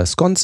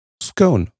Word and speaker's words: scone: Tatsächlich gibt es zwei richtige Aussprachen. Im amerikanischen Englisch, im Süden Englands und in der englischen Mittelschicht herrscht die Aussprache Scone vor. Die etwas härtere Aussprache Scone wird eher mit dem Norden Englands und der Arbeiterklasse in Verbindung gebracht.